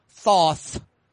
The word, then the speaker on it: Thoth: Around 1, it's Toth. Or as Joe pronounces it, Thoth.